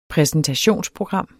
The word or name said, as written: præsentationsprogram